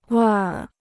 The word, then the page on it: 味儿: wèir